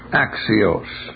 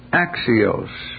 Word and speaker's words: Axios: Axios. With the long O, which is shaped like this. There's a footnote there, see? Go down to the footnote. Axios.